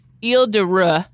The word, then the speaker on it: Île de Ré: There's some major innovation happening on a island off the coast of France called Île de Ré.